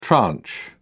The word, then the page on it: tranche: British English